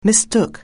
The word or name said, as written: mistake